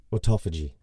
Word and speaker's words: autophagy: Is that definitely how you pronounce it? – Yes, autophagy. – Autophagy? – Autophagy.